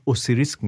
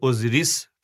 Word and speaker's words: OSIRIS: آقا بالاخره اوسیریس رکس یا اوزیریس رکس شما اوسیریس میگین بهش من اوسیریس میگم به خاطر اینکه قابل جستجوتر بشه ولی اوزیریس تلفظ درسترشه